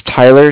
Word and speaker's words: Tyler: My name is Tyler, T-Y-L-E-R. – Mm-hmm. – And I'm calling with Cardiff. It's spelled C-A-R-D-I-F-F, as in Frank Frank.